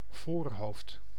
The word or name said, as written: forehead